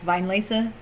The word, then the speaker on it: Weinlesefest: The Wine Lace Fest. Which I think is actually pronounced Vine Lace.